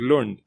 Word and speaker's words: Lund: I'm going to stay away from pronouncing it because that's exactly how it's spelled. – It's not obviously... It's obviously Lund.